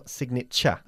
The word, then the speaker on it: signature: Fijaros cómo pronuncio signature. Yeah? – Signature. – At the end, cha. Signature.